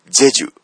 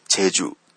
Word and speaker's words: チェジュ: あれはですね、チェジュ、日本人からするとチェジュだから、 CHEJU、チェジュなんじゃないかなと思いがちなんですけども、これ、ローマ字で書くとですね、 JEJU、ジェジュっていう風に書きます。まあ、もちろん韓国人が発音すると、五島の濁音は発音しないので、ジェジュと書いて、チェジュという風に発音するんです。